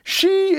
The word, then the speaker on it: Xi: She is pronounced very much like the English female pronoun she.